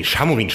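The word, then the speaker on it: Šamorín: Aber also jetzt am besten erwerben und am Wochenende signieren lassen in Samurin. Ja, genau. Nee, Chamorin spricht man es, glaube ich.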